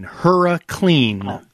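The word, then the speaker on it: HurriClean: H-U-R-R-I, clean, HuraClean, like hurricane.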